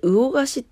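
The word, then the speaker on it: 魚河岸: えー、っとね、各地の、えー、魚菓子では、これで魚菓子って読むんだね。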